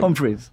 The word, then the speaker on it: Humphries: Humphries.